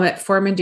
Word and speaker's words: metformin: And so at the end of the trial, and they compared the metformin and the lifestyle modification to placebo, the lifestyle intervention reduced the incidence of type 2 diabetes by 58%, while metformin decreased the incidence by 31%.